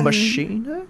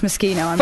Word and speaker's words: Moschino: Moschino? – Yes. – Oh, but it's pronounced Moschino.